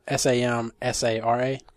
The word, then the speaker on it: Samsara: Spelled like Sam and Sarah, but one word and without the H at the end. – So Samsara? – Correct.